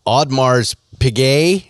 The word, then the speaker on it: Audemars Piguet: Prices for Rolex, Patek, and Audemars Piguet, is that how we pronounce that? – Close enough.